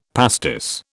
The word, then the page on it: pastis: British English